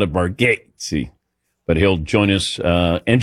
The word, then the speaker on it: Bargatze: But then when we met him in Vegas, or Tahoe, he said, no, it's, he goes, my family actually mispronounces it, but it's Nate Bargatze.